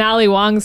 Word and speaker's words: Ali Wong: And an Ali Wong special, or not? Yeah, did I say that right? – Ali you did, Wong.